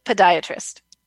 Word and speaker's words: podiatrist: Podiatrist. – Podiatrist, that's how you say it? – Yeah. It's a weird word.